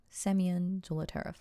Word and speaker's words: Zolotaryov: According to people who knew Zolotaryov, how did I pronounce his name? Semyon Zolotaryov, right? Yeah.